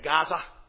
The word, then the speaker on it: Gaza: And when they came across the sea and set up shop in Gaza, pronounced today Gaza,